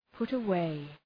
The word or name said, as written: put away